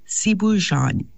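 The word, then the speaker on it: ceebu jën: It's pronounced, I think, sibujan.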